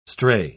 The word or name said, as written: stray